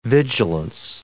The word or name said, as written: vigilance